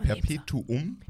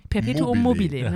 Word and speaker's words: Perpetuum mobile: Ich weiß gar nicht, ob ich Perpetuum mobile überhaupt richtig ausspreche. Ich glaube, es ist Perpetuum mobile. Perpetuum mobile, ne? Ja, genau. Aber das genau.